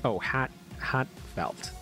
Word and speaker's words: Hatfelt: H-A-T-F-E-L-T. I believe it's pronounced... Hotfelt. Okay, yeah, it's pronounced Hatfelt.